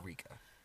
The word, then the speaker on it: Rika: Uh, so I would say Rika. Because so on the on the other hand, I would mm. also I would look at how your name is spelled. – Mm-hmm. – And for me, because mm-hmm. it's an mm. R, there is no mm. L. Mm-hmm. – It's not like, oh, Rika.